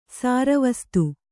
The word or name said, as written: ಸರ್ವಸಾಧು